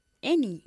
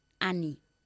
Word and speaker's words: any: ¿Y cómo se pronuncia any? – Any. – ¿Y cómo no se pronuncia? – Ani.